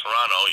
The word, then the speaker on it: Toronto: But it's so I learned that it's Toronto. You don't pronounce the T.